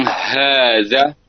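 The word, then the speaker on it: Haza (هذا): Ya biasa aja, ha. Haza. Penekanan dari sisi ha tebal ya. – Ha tebal. – Ha tebalnya itu. Terkadang karena kurang ini hazanya tipis, haza gitu, enggak haza.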